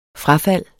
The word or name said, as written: frafald